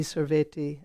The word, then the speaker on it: sorvete: the tea is more of a T sound and okay. – like maybe sorvete.